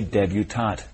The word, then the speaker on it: debutante: However, there is a related word that is pronounced with the T sound, debutante. – That's right. A young woman who is making a formal debut into society is a debutante, not debutante.